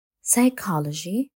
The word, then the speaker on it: psychology: الكلمات التي تبدأ ب pn و ps تحتوي على حرف صامت p لذلك فإن كلمات مثل pterodactyl psychology ونيمونيا تحتوي على حرف صامد